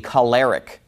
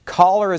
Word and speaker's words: choler: It's spelled C-H-O-L-E-R. He pronounces it choler.